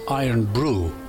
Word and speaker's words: Irn-Bru: Anyway, I bought a bottle of the stuff, only to find out that it's pronounced Iron Brew, and it is Scotland's number one soft drink.